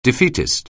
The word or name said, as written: defeatist